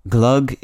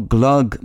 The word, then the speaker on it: glögg: But although glögg may look like it should rhyme with that other notable holiday beverage, eggnog, the two aren't quite a perfect match. The vowel sound, the O in glögg, is pronounced like either the vowel of nut or the vowel of foot, or the more foreign-sounding vowel in bœuf, the French word for beef.